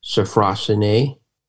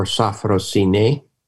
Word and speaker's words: sophrosyne: One of the most important concepts in ancient Greece is pronounced either sophrosyne or sophrosyne, and it means self-knowledge and self-restraint.